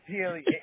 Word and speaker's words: Healy: It's Healy.